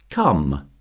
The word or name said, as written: come